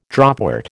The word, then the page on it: dropwort: American English